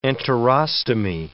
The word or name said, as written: enterostomy